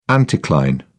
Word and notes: anticline: British English

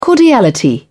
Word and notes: cordiality: British English

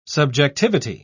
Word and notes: subjectivity: British English

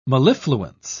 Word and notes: mellifluence: British English

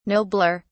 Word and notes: nobler: British English

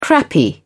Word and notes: crappy: British English

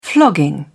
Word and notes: flogging: British English

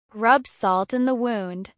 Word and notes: rub salt in the wound: American English

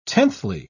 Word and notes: tenthly: British English

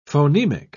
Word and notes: phonemic: British English

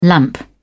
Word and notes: lamp: British English

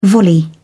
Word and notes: volley: British English

